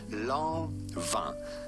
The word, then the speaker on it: Lanvin: We are looking at how to pronounce Lanvin with the typical French pronunciation. Lanvin. Lanvin. So do not say Lanvin, but rather Lanvin. Lanvin.